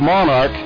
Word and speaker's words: Monark: Pronounced Monarch, like royalty, only spelt different, M-O for Missouri and A-R-K for Arkansas, M-O-N-A-R-K, Monarch Realty.